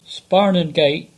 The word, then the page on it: Sparnon Gate: English